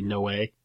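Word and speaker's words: Noë: No is N-O-E. But I think there's an accent on the end that isn't showing up in my notes here. So maybe Noah.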